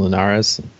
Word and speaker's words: Linares: So I didn't watch the last fight between Campbell and Linares.